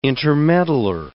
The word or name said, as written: intermeddler